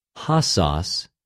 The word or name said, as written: ὁσος